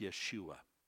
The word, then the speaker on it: Yeshua: In the Old Testament, you see a bunch of Joshuas, and which is pronounced Yeshua, which means salvation.